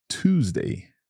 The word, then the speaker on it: Tuesday: So this pronunciation change or difficulty comes from the first two letters of Tuesday, which are T-U. – Yeah, and these letters change their pronunciation in other words too. It's not just Tuesday. – That's right. So when we have the letters T-U in the middle of a word, we always pronounce them as ch, just like the first sound in cheese, the food.